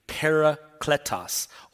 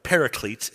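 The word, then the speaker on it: parakletos (παράκλητος): And it's pronounced, you can see, parakletos, or paraclete is how you might have heard it before.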